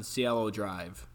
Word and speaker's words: Cielo Drive: And they're kind of driving home, and we see they pull up into this private driveway, and it's camera zooms in on Cielo Drive. I think I'm pronouncing that correctly. – Yep.